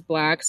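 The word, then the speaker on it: 6lack: It says Atlanta, Georgia, Ricardo Valdez Valentin Jr., known professionally as Black, stylized as 6 lakh, pronounced black. So it's pronounced black.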